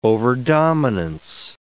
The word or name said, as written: overdominance